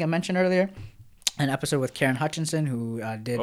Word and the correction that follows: Hutchison: Oh, Hutchison. Yo, don't butcher that.